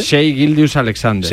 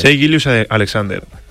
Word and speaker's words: Shai Gilgeous-Alexander: Shai Gilgeous-Alexander. – Shai Gilgeous-Alexander.